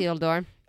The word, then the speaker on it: Isildur: Isildur.